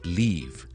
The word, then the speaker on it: leave: 尽量将个 V 音那一个的前面整个响音呢我拉长一点。俾多个例子大家，譬如我一塊樹葉 L-E-A-F 係 leaf a leaf，但係如果我話要離開咧 L-E-A-V-E 咧，我哋 leave leave leave 長好多嘅，OK 長好多嘅 leave。